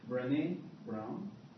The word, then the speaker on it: Brené: Brené Brown. Did I say it right? – Yeah. Brené, so it sounds like Brené, but there's a B in the beginning.